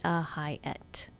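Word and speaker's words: Hiett: So if you just think of it phonetically, Cynthia Hyatt. That's how my name is spelled.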